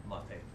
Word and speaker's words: mate: M-A-T-A? – M-A-T-E. Spelled like mate. – Mate.